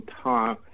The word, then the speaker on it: Thom: So it's, the H is silent in Thom, and the H is silent in Thom.